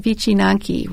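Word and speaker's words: wycinanki: I wanted to focus a little bit on a Polish folk art, and I chose something called wicinanki, which is paper cutting. And I'm glad you pronounced that for me. – Yes, that's as I understand, that's what my Polish friends tell me.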